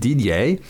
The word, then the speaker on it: Didier: So I said Didier, like he's spelled in French, D-I-D-I-E-R. They spelled it without, they left out an I. God knows why. So it's spelled D-I-D-E-R. So that's Dider. Sounds like Dider. And a French guy noticed it. Because I think he noticed it. But he noticed it. And he called me Dider.